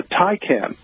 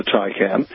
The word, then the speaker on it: Taycan: But you may recall that they introduced just last year the vehicle called the Taycan. T-A-Y-C-A-N is how they spell it, and it is, yeah, you might think of it sort of as an all-electric 911.